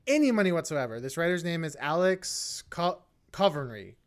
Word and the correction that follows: Convery: Let me see if I can pronounce his last name. – Covery? – Convery. Alex Convery.